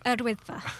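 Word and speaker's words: Yr Wyddfa: Recent hot weather means there's been a bumper summer crowds at Yawadifa. Is that how you pronounce it? Erdwydfa. – Thank you.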